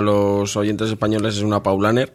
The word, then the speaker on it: Paulaner: Aquí es Paulaner.